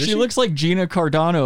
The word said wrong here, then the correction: Carano: The one who Carano? – Got fired? Yeah. Did I say Cardano? – You did.